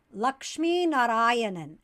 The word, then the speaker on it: Lakshminarayanan: So my last name in my OG language, Tamil, is pronounced Lakshminarayanan. And so that's really hard for non-native Tamil speakers to say because you kind of have to roll an R.